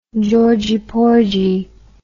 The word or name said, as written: georgie porgie